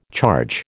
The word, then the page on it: charge: American English